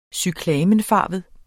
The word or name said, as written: cyklamenfarvet